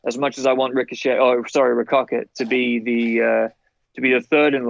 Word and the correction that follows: Ricochet: line, it's Ricochet. That's how you pronounce it.